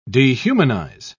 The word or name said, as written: dehumanize